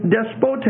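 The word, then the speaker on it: despotes (δεσπότης): The word for Lord in the Greek is despotes. It's a different word. It's not the word kurios, the normal word. D-E-S-P-O-T-E-S. And that last E is a long E.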